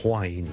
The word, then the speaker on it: whine: 其實如果你學某一啲 accent 咧，係的確咧係會讀 whine 咁樣嘅。例如愛爾蘭嘅英文咧，好多時候咧都會聽到。 Wine W-I-N-E 同埋 W-H-I-N-E 發音係唔同嘅，